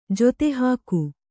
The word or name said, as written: ಜೊತೆಹಾಕು